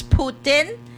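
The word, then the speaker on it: poutine: pronounce, but it's Putin.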